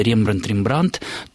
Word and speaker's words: Рембрандт: И Рембрандт – это тоже попытка произношения голландской фамилии на французский манер. То есть, если со словом «Пикассо» – «Пикассо» возможны варианты, и все-таки словари регистрируют как предпочтительную форму вот эту вот уже устоявшуюся «Пикассо» на французский манер, то Рембрандта мы все-таки, наверное, должны называть Рембрандтом.